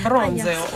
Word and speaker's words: bronzeo: Qui si beve vino bianco bronzeo. Bronzeo. – C'è l'accento sulla O per bronzeo.